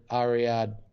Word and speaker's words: Ariadne: Ariadne. I want to know how it's pronounced. – Bacchus and Ariadne. – Well, it's Bacchus. It's pronounced Bacchus and Ariadne. You know, Ariadne must be Bacchus and Ariadne.